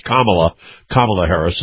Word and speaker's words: Kamala Harris: And, by the way, it's Kamala Harris is where you pronounce that.